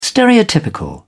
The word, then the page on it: stereotypical: British English